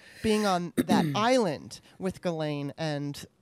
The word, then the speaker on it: Ghislaine: Unfortunately, so did his girlfriend, who I'm not sure if I'm going to say her name right, which is, I think, is Ghislaine Maxwell. – That's what I heard, Ghislaine.